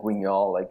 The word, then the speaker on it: Guignol: Yeah, Guignol, that's taken from like Grand Guignol, the theater.